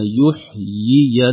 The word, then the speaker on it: يحيي: بقادر على أن يحيي الموتى، لو نلاحظ في النطق قلنا يحيي الموتى، نطقنا يا أين، الياء الأولى مكسورة والثانية مفتوحة، وفي الواقع المكتوب عندنا في المصحف ياء واحدة، رسم الكلمة فيه ياء واحدة،